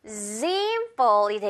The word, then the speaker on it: example: 자, 여기서는 지금 우리 Z 발음 넣어줘야 돼요. 그래서 example이 되겠죠. example. 이렇게 Z로 연결해주면서 이 AM 이렇게 연결해주면 돼요.